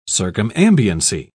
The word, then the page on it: circumambiency: British English